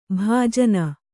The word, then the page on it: ಭಜನೆ: bhajane; Kannada